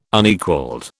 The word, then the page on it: Unequalled: British English